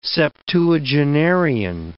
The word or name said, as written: septuagenarian